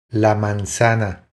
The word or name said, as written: La manzana